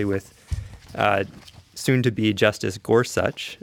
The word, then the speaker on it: Gorsuch: And by the way, listening to the confirmation hearings, I learned his name is pronounced Gorsuch.